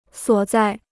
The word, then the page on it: 所在: suǒ zài